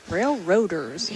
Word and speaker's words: Railroaders: I mispronounced that earlier, so. – Oh, did you say Rail Riders? – I think I said Rail Riders, but that's usually the common name, but yeah. This is. – Railroaders.